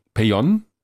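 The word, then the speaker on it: Pellón: Schreibt sich mit Doppel-L, spricht sich aber so wie das Mallorca aus, also Peyon vom European Space Operation Center in Darmstadt.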